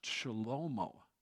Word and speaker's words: Shlomo: And Solomon, it's not pronounced that way in Hebrew. His name in Hebrew is pronounced Shlomo.